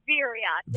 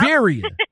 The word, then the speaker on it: Veriha: Say it like it sound. Veriha, everybody. Veriha Trucking out of Marinette, Wisconsin.